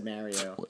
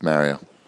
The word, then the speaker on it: Mario: Oh, did I say it wrong? Are you making fun of me? – Yeah, you said Mario. – Whatever. It's Mario. So, yeah.